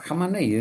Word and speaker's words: Khamenei: Khamenei es yo creo que la pronunciación correcta.